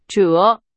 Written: 折

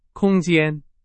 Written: 空间

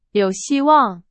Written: 希望

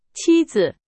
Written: 妻子

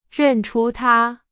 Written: 认出

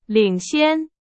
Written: 领先

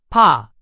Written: 怕

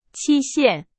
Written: 期限

